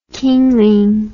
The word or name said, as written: kingling